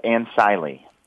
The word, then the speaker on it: ANCILE: And how do you pronounce your company? Is it ANCILE or ANCILE? I want to make that right. – It's actually three syllables, ANCILE.